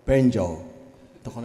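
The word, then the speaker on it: banjo: アメリカではこれをベンジョーとこのように言います。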